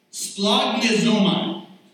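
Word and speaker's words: splanchnizomai: It is pronounced, ready for this? Splognizomai. Splognizomai.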